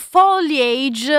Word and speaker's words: foliage: Parlavamo di foliage a proposito, perché ci ricordiamo che la pronuncia corretta è quella inglese, non alla francese, come ho erroneamente prima detto.